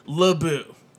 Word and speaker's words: Laboube: So it's pronounced Labou. It's pronounced L-A-B-O-O. Like you're booing someone. – Yeah. – But it's spelled much differently. L-A-B-O-U-B-E.